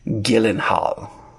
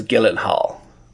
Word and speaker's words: Gyllenhaal: and I only know this because one time I watched an interview with his sister, and they asked how to pronounce their last name, and she's like, "Ah, Gyllenhaal's fine, but like the proper pronunciation is Gyllenhaal."